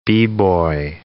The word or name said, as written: B-boy